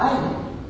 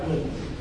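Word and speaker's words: Einem: Вообще по-немецки фамилия Эйлен, это русское произношение, вообще она произносится как «Айлен». И вот немец произнесет эту фамилию именно так. Ну, у нас это вот прижилось как Эйлен.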